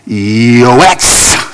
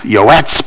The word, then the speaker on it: yoetz: That's the O. That's the Y. What looks like a hockey stick with a dot over it is their long O. That's an E sound. And then that funny looking thing at the end is a TS or a TZ. Yoetz.